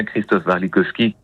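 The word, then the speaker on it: Christophe Warlikowski: C'est Europa, c'est d'après le serment d'Europe de Wajid Mouawad et c'est mis en scène par Christophe Warilowski. J'espère que j'ai peut-être pas bien prononcé son nom. – Non, ça fait bien Christophe Warilowski.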